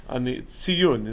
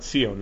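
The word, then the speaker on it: Tziyun (ציון): It's just footnotes on the Tziyun is, you know, to quote or to cite. No, it's not Tzion. It has nothing to do with Zionism. A lot of people mispronounce it.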